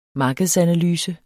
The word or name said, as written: markedsanalyse